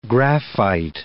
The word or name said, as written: graphite